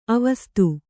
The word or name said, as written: ಆವಸಿತ